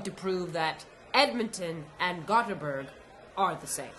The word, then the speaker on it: Gothenburg: And you've also spent some time in Gothenburg, Sweden.